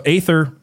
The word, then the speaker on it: aether: So aether, and people say ether, but when you look it up, it actually is, I believe, supposed to be pronounced aether.